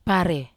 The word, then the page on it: pare: wamesa